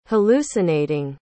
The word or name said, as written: hallucinating